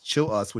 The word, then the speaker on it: Chilous: Well, primarily on Twitter or X, whatever you want to call it, it's Chill Us, which is how you say my last name, CHILL_US.